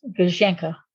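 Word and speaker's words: Gouzenko: I would pronounce it Guzhenko, and that is incorrect. It was Guzhenko with an A sound on the end.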